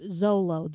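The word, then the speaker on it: Xolo: It's so hard to pronounce. I mean, it looks like Mr. McClixifix, whatever it was from Superman comics. – Yeah, but it's pronounced Zolo.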